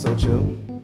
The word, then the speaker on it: Seaux: I just say my name is Socho. You spell it with like a Frenchy vibe, S E A U X.